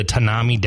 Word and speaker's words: Tanami: But it's the northern edge of the Tanami Desert.